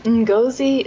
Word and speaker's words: Ngozi: Ngozi is how you say her first name.